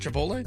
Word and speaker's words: Chipotle: Can I get Starbucks while I'm at Chipotle? – That is the first time has ever said Chipotle correctly.